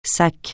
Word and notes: sac: francais

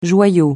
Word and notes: joyau: francais

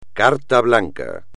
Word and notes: carta blanca: espagnol

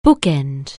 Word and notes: bookend: anglais

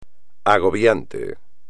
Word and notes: agobiante: espagnol

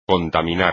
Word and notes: contaminar: espagnol

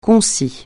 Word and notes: concis: francais

